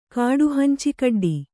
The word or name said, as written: ಕಾಡುಹಂಚಿಕಡ್ಡಿ